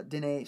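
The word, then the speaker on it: Denis: Uh, Dennis, uh, Dene, sorry, his name's Dene.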